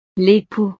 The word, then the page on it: l'epoux: frances